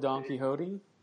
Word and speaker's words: Don Quixote: The Man Who Killed Don Quixote? – Yeah, there you go.